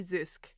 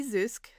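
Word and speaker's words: Zysk: Zusk. This Vacant Body is by T. Zusk, also known as Reaper Sun, and that last name, Zusk, is Z-Y-S-K. I'm hoping I'm pronouncing it right. That's the German pronunciation.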